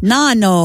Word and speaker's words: Nonno: Nonno. – Nonno. – That is Italian for grandfather. – Yeah. – Nonno. It's spelled N-O-N-N-O.